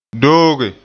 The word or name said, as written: dooge